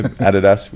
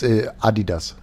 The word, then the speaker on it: Adidas: Steph K's got me sorted with all the gear and stuff, so I got some fresh Adidas shoes on, or Adidas, I don't know what the pronunciation is out here. Adidas, we say it in Australia. – It's Adidas.